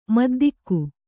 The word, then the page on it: ಮಿದ್ದಿಗೆ: middige